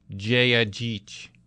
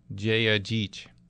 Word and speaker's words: Jedgeech: Just got a text in that the reporter on Channel 4 pronounced it Jayajich. Jayajich. I think that's it. How it says it's spelled out there. J-E-D-G-E-E-C-H. Jayajich.